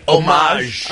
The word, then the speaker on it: homage: No H. Homage. – Homage. Like, homage. Like omelet.